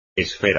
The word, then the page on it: esfera: espagnol